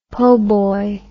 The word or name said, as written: poboy